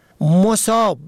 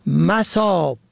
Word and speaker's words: مصاب: مصاب به ضم اول به معنای مصیبت زده، سختی دیده، مصیبت رسیده. مصاب به فتح اول جمع مصب به معنای محل، مورد، موضع. مصاب به کسر اول به مفهوم سیراب، پر و فرد سیراب و پر شده از آب.